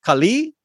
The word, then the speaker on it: Kali: A better pronunciation would be Kali, and that is correct.